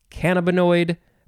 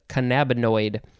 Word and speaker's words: cannabinoid: I've also heard it pronounced cannabinoid. Probably other ways, but this one says cannabinoid. Noun from 1967, any of various chemical constituents, as THC or cannabinol, of cannabis or marijuana.